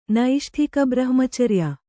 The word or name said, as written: ನೈಷ್ಠಿಕಬ್ರಹ್ಮಚರ್ಯ